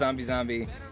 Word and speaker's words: ZombieXZombie: It's just pronounced ZombieZombie, not ZombieXZombie or ZombieNZombie. It was basically our workaround, and we don't really care. We know it's ZombieZombie, and we like it that way, and that's what it is.